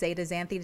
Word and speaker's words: zeaxanthin: Is there a certain way that you feel like it's best to prepare them to maximize the lutein and the zeaxanthin? Is that what it is? Zeaxanthin? Is that how you say it? – Zeaxanthin is how pronounced.